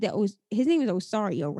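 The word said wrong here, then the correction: Isauro: right? That's how you say it? – Asaro, okay.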